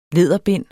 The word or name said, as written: læderbind